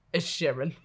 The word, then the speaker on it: Inisherin: Inisherin.